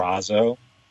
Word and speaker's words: Purrazzo: And Deonna Purrazzo, how do you say your last Purrazzo? – Name? – Purrazzo. – Purrazzo.